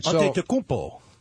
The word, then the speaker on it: Antetokounmpo: Antetokounmpo. – Yeah, is that how you say it? – Yeah.